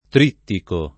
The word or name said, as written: trittico